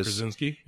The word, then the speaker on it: Krasinski: John Krasinski, I think that's how you say his name. You know, he's from The Office. – Yeah, Krasinski.